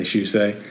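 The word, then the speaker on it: Shusett: So after he's made Dark Star, this guy called Ronald Shusey, I think I'm saying it right. – Yeah. – Say Shusey.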